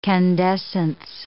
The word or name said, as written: candescence